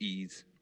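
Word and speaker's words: Ys: So there's a game called Ease, and it's like that Wise, but it's pronounced Ease.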